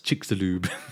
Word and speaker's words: Chicxulub: Spelt Chicxulub. So the internet told me that's pronounced Chicxulub. – Chicxulub.